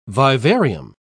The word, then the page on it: vivarium: British English